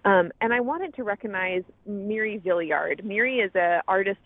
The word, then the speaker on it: Moira: Her name is spelled Moira, M-O-I-R-A, but it's pronounced Miri. She always says theory, but with an M. So Miri Villiard, just before the pandemic hit, said, you know what, I'm going to go it alone.